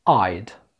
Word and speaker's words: I'd: ملاحظين I would، وعلى فكرة تقدر تكتبها مختصرة بالشكل ده I would، نطقها بقى كده I'd I'd، كأنها مكتوبة A-Y-D، فبنقول I'd better I'd better.